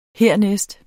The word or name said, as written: hernæst